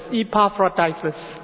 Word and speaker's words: Epaphroditus: This is pronounced as Epaphroditus.